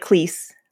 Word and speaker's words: Cleese: It's the Muppet Show with our special guest star, Mr. John Cleese! – So here he pronounces it like Cleese, like lease, as opposed to rhyming with cheese.